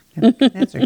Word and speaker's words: Nebuchadnezzar: Nebuchadnezzar, you said it correctly.